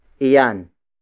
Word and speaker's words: iyan: The next pronoun is iyan. Iyan. Iyan. Iyan is spelled as I-Y-A-N.